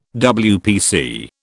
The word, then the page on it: WPC: British English